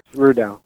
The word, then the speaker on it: Rudaw: Is it pronounced Rudaw? – Rudaw.